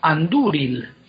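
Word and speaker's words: Andúril: Las tildes lo que te dicen es si la vocal es larga o corta. Y cuando la segunda es larga, la sílaba es fuerte y se queda con el acento. Como por ejemplo, anduril.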